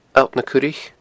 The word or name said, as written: alltnacuraich